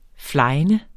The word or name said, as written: flegne